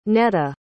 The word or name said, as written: Neta